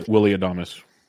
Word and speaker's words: Adames: So what we're going to want to do after this, after I explain a little bit, is go take a look at Wiley Adams, because or Adames. I don't remember how to spell it. – Willie Adames.